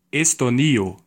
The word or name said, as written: Estonia